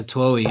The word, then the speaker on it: Twohy: What's his name? Twohy. I can't remember how to pronounce it. – Yeah, Twohy, yeah.